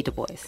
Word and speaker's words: Du Bois: Sorry to correct you, Lynette, but I think it's Du Bois. Mm-hmm. W.E.B. Du Bois.